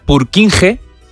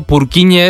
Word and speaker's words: Purkinje: Es que se escribe, en español sería Purkinje. – Purkinje. – Pero Purkinje es en checo, que es porque es el apellido del descubridor.